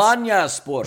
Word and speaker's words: Alanyaspor: Diego started and played the first 71 minutes in Fenerbahce's 2-1 loss to Alanyaspor. I believe I pronounced that badly. But either way, Turkish Super League Giants. – Alanyaspor. – Sure.